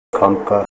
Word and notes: kamka: Ninilchik Russian

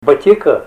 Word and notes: batika: Ninilchik Russian

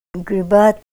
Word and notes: gribat: Ninilchik Russian

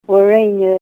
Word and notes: warena: Ninilchik Russian

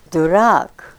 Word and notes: durak: Ninilchik Russian

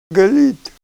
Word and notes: gal'it: Ninilchik Russian

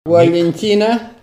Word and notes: walentina: Ninilchik Russian

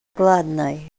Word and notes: gladnay: Ninilchik Russian

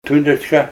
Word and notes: tundrachka: Ninilchik Russian

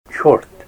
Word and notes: chort: Ninilchik Russian

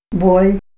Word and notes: bol': Ninilchik Russian

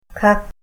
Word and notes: kak: Ninilchik Russian

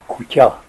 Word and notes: kutya: Ninilchik Russian

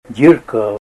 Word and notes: dyirka: Ninilchik Russian

